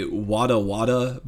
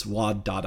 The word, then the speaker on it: Wadada: And moving on up to number 9, which is Wadada. Did I say that right? – Wadada. Yeah, that would be my guess. I have a bad habit of wanting to say Wada Wada, but it's Wadada.